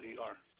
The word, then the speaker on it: Mueller: Robert or Bob Mueller, Mueller.